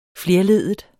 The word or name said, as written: flerleddet